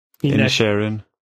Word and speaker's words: Inisherin: är att Banshees of Inisherin jag vet inte om man uttalar det. – Inisherin.